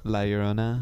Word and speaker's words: La Llorona: The curse of, I don't know how to say it right. – La Llorona. Yeah, there you go.